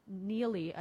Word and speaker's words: Neiley: The 9th Judicial District Chief Judge John Neely, I believe is how we pronounce it, N-E-I-L-E-Y, Neely.